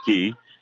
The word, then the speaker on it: Qui: met at midcourt there by Key. Spells her name. Q-U-I pronounces it Key.